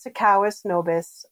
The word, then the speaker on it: Sikowis Nobiss: My name is Sikowis Nobiss.